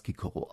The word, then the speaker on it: Cicero: Zu Ciceros eigener Zeit ist mit Sicherheit Cicero gesagt worden, denn es gibt griechische Quellen aus der Zeit und die überschreiben das mit K, mit Kappa im Griechischen. Sie hätten auch die Möglichkeit gehabt, das mit Säter zu umschreiben. Und insofern ist es klar, dass es damals Kikoro ausgesprochen wird.